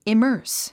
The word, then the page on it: immerse: imə́ːrs; イマース